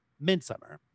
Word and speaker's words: Midsummer: It's because it's actually pronounced with the same emphasis as in English, Midsummer.